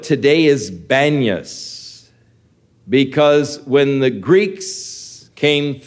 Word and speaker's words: Banyas: But the Arabs had difficulty pronouncing P's, and they said Banyas for Panias. And so the name has come to be Banyas now of this area that was once Caesarea Philippi.